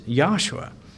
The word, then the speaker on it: Yahshua: in the scripture we have in English, says Joshua, but it's Yahshua. And it's spelled Yod-Heh-Wah-Shin-Ayin, the same way we spell the Savior's name, Yahshua.